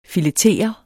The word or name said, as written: Filetere